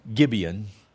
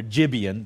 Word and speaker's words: Gibeon: And so it's pretty much direct translation of Gibeon. We pronounce it with a G. Strong G, but if you put the J sound in there, Gibeon, that's where they get the name still today.